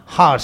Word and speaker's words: Hagar: Hagar, sí. La H se pronuncia.